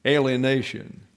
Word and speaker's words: alienation: Hostile is fine, but the better English word to describe James's meaning of ekthra in this context is alienation. Excuse me, alienation. I put an extra syllable in. My tongue was tangled.